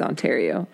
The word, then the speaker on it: Ontario: As a Canadian citizen, allow me to correct you. Not correct you, actually affirm you that it is Ontario.